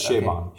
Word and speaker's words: Chabon: I know, it's my first Michael Chabon. Did I pronounce his name correctly? – You did. It's Chabon. – Okay. Chabon.